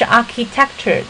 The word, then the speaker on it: architecture: 另外就是 architecture 这个 ch 这个字母组合的发音是发 k 啊，发克的音。